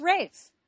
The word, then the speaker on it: Raph: His name is Rafe, but it's not spelled like Ralph, like Rafe Fiennes. – Yeah. – This guy's name is actually R-A-P-H. It's Rafe.